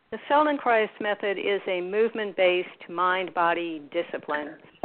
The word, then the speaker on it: Feldenkrais: And as I understand, you're also a practitioner, I guess, a teacher of the, help me say this correctly, the Feldenkrais method? – Feldenkrais method, that's correct. Yes.